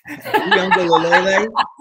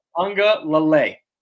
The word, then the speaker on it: Uiagalelei: Uiagalelei. – It's Lele. – Uiagalelei.